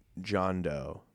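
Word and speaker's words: Jean Deaux: You would like John Doe, but it's spelled like Jean Dukes. – Uh-huh. – It's like all like French and shit.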